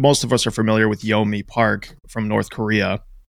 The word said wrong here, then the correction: Yeonmi Park: Did I pronounce that right, by the way? Is that how you say it? – Yeonmi Park. Yeonmi Park.